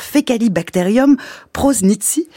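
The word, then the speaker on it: Faecalibacterium prausnitzii: Vous travaillez notamment sur Fecalibacterium prosnitzii.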